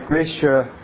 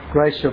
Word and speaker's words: Gracia: This book, In the Presence of Mine Enemies, is the book written by Gracia, as it's properly pronounced, Gracia Burnham, the wife of Martin Burnham, the couple that were taken hostage by the Agdeus-Seif rebels in the Philippines.